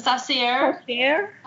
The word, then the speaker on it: sorcier: You know, he was a shashar, is that how you say it? – A sorcier.